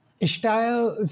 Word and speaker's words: Ishtail: Ishtail, so it's a word I grew up hearing, and it's basically accented style. It's the way some Indian people would pronounce style. Mm-hmm. And, that might be in South India, that might sound more like style or estyle. So Ishtail is a sort of a Bombay version of that accenting, but it has its own currency.